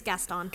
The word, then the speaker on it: Gaston: But, like, it kind of then makes you think that Gaston is not that bad, right? – I don't know. Gaston's just a jerk. – Is it Gaston or? – It's Gaston. – Gaston.